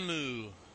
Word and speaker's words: Dormammu: his original power with his cloak comes from Dormammu. I don't know how you say it, but you know what I'm talking Dormammu. – About. – Dormammu.